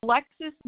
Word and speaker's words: Alexis: Alexis Munich. It's spelled A-L-E-X-I-S. Last name is M-U-E-N-C-H.